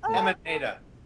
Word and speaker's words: Hemant Mehta: Hemant Mehta.